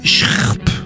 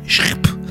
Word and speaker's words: SHHRRP: La preuve maintenant avec ce titre difficilement prononçable, Schrp, ça s'écrit comme ça, enfin ça se dit comme ça, mais ça s'écrit S-H-H-R-R-P, Schrp.